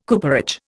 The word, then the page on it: cooperage: Indian